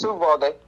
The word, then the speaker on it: Uvalde: No, it's Uvalde.